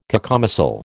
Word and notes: cacomistle: American English

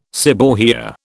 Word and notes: seborrhoea: British English

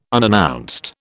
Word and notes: unannounced: British English

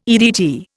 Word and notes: EDT: Indian

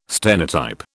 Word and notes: stenotype: British English

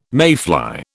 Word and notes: mayfly: British English

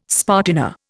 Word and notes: spartina: Indian

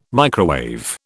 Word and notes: microwave: British English

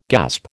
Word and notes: gasp: American English

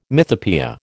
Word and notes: mythopoeia: American English

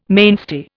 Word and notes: mainstay: Indian

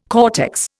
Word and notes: cortex: Indian

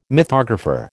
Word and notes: mythographer: American English

